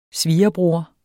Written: svirebror

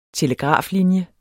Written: telegraflinje

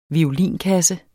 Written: violinkasse

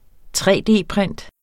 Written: tre-d-print

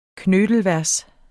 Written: knittelvers

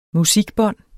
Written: musikbånd

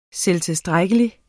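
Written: SELVTILSTRÆKKELIGT

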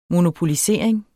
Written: monopolisering

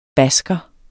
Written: basker

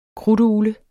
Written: krudtugle

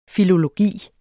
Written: filologi

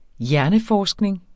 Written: hjerneforskning

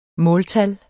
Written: måltal

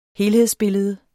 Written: helhedsbillede

